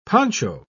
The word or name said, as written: poncho